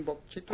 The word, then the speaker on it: Bok Chitto: How do I pronounce that? – Crossing Bok Chitto? Crossing Bo- uh, right? but there's, there's no wrong way to pronounce it, depending on what part of Mississippi or Oklahoma you are.